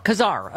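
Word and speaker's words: Casar: Representative Greg Kazar, am I saying that right this time? – Kazar, okay, from the very great state of Texas.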